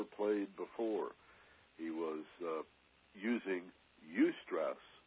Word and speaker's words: eustress: There is something called eustress. This is an important concept, more than a word. Eustress is spelled E-U and the word stress. E-U-S-T-R-E-S-S. Eustress.